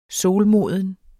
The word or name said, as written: solmoden